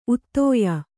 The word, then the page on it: ಉದಯ: udaya